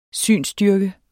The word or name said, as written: synsstyrke